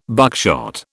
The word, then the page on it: buckshot: British English